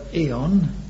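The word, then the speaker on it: aeon: This is the word eon, spelt with an A but pronounced with the E, eon, the word eon, and, and it really means the age.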